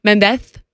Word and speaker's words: Mendez: That's how you say his name. – Mendez. – Like in España. They don't the este. Mendez.